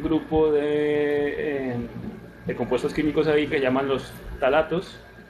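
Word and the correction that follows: ftalatos: creo que lo pronuncié mal, es con F al principio, F talatos.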